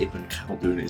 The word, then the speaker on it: Ibn Khaldun: I think Ibn Khaldun is right. Ibn Khaldun, yeah.